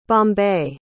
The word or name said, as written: bombay